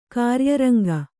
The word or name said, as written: ಕಾರ್ಯರಂಗ